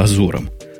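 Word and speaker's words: Azure: А ты как каким э-, э-, Эжуром назвал? – Ну, там несколько произношений есть.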